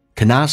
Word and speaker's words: cannot: 嘴角咧到极限 cannot 的 T 不发音，这叫略音 cannot